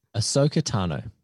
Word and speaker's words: Ahsoka Tano: Ahsoka Tano.